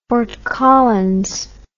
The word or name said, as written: Fort Collins